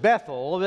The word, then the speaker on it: Bethel: Now, in Texas, we pronounce it Bethel. The Israelites, they still pronounce it to this day, Bethel. The reason is, it's a compound word, two words, bet, B-E-T-H, their H is silent. So it's Bethel, L is the prefix for God, the word bet is house, house of God.